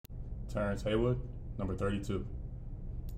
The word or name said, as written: Terence Haywood